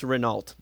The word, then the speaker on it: Renault: It's now pronounced Renault, by the way. That's one thing. It's Renault.